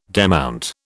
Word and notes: demount: British English

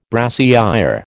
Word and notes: brassière: American English

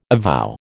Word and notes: avow: American English

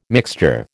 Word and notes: mixture: American English

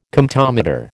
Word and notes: Comptometer: American English

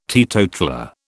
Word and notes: teetotaller: British English

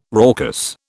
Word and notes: raucous: British English

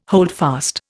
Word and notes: holdfast: Indian

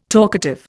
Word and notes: talkative: Indian

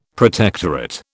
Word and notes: protectorate: British English

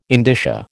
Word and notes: indicia: American English

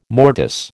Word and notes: mortise: American English

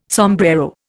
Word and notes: sombrero: Indian

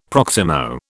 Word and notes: proximo: British English

